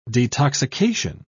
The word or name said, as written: detoxication